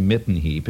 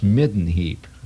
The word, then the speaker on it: midden heap: Spelled with two D's. It's not a mitten heap. It's midden heap.